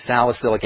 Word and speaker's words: salicylic: which is salicylic acid. And that's spelled S-A-L-I-C-Y-L-I-C, salicylic acid.